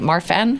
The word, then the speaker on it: Marfan: Marfan? – Marfan, yes.